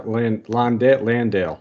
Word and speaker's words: Landale: And then, like you said, with Jakob and Jock, how do you say his last name? Landeline? Yeah. – Jock Landale. Oh man, we're butchering this.